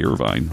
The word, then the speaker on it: Irvine: An example, Irvin, for example, mm-hmm. That we say in Bemidji for Irvin Avenue, but in California they say Irvine, of course.